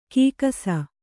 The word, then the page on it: ಕಕ್ಕಸ್ಸು: kakkassu